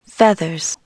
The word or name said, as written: feathers